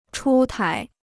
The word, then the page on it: 出台: chū tái